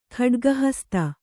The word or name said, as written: ಖಡ್ಗಹಸ್ತ